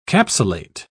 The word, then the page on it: capsulate: British English